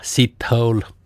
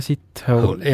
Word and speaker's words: Sithole: Tema nimi, kuula nüüd, on Jaja Sithole. – Jaja Sithole. – Jah. – Kuidas niimoodi tundu? Hääldataksegi? – No kirjutatakse S-I-T ja hole, nagu ingliskeeles on see.